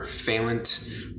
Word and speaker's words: Fehlandt: You know, you can check me out on Instagram at Oliver Fehlandt. Last name is spelled Fehlandt.